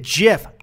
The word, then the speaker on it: GIF: You could say, oh, the first word is graphics, so it's a hard G, and I'll that's fine, because then it's GIF.